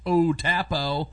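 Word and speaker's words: U-Tapao: And Gene Ponce, a former DOD and military intelligence officer, is heading the search near the former Utapu Air Force Base. That's pronounced Utapo.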